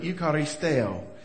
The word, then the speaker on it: eucharisteo: You know, Paul uses this Greek word, eucharisteo, is how you pronounce it.